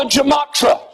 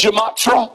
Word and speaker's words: gematria: using a method called the gematria, usando un método matemático llamado la, how do you pronounce it? – Gematria. – Gematria.